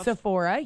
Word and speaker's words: Sephora: Sephora, yes, okay.